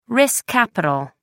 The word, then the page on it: risk capital: American English